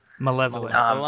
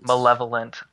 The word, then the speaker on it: malevolent: And it wasn't until a kill afterwards where we didn't record that I went ahead and brought my malevolent shield or malevolent shield. – Malevolent. – Malevolent. – I have never been able to pronounce that one.